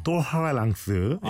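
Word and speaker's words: tolérance: 아그러구나 그러니까 똘레랑스 아, 이게 아, 발음 좀 자, 제대로 해봐요. – 똘하랑스. – 아 예. 그거예요.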